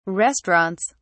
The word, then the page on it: restaurants: British English